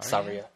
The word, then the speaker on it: Saria: Saria, yeah.